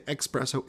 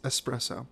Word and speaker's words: espresso: a nice espresso a lot of Americans say espresso. It's espresso. – Yeah, there's no X uh, there.